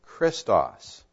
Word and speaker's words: Christos: It's a transliteration, because the Greek letters, you would pronounce them Christos.